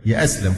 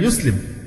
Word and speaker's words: يسلم: يأسلم فلان عايز يأسلم، هي الفعل يسلم، إنما يأسلم دي كلمة من اختراع الناس في هذا العصر من الركاكة وضعف اللغة الشديد، حتى في كلمة تمس أصل الدين مش عارفين انطقها صح، مش هنقول زي فلسطين كله يقولها فلسطين، ماشي مش مشكلة، لكن تيجي في عبارة الإسلام نفسها وانطقها غلط يأسلم، إيه أسلم دي؟ من أين أتيت بهذه اللغة؟ يسلم، يسلم إسلام، لما يأسلم كلام في غاية الركاكة والضعف والهزال، ما يليق أبدا بأي مسلم يقول يأسلم، إنما هي يسلم.